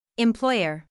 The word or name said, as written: employer